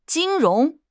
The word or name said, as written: 金融